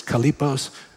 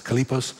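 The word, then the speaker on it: kalipos (χαλεποί): The Greek word is kalipos. Looks like it's spelled chalipos, but it's kalipos.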